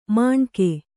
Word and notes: ಮಾಣ್ಕೆ: Kannada

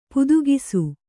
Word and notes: ಪುದುಗಿಸು: kannada

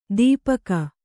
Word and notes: ದೀಪಕ: dīpaka

